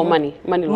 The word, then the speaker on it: Money Long: I don't want to mispronounce her name, but I think it's Mooney Long. – Oh, Money Long.